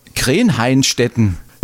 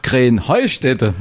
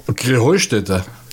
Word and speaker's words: Krähenheustätte: Ja, ihr saget immer Krähenhainstetten. Und dabei heißt es doch Krähenheustätte, oder wie es? – Krähenheustätte. – Ja, genau, und das die Betonung auf dem Heu.